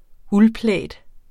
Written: uldplaid

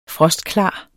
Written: frostklar

